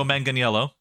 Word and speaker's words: Manganiello: Yeah, Joe Manganiello.